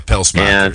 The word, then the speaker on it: Pelsmakers: This uh, you got to get the s in there. Yeah, Pelsmakers.